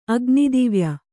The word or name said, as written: ಅಗ್ನಿದಿವ್ಯ